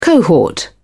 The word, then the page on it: cohort: British English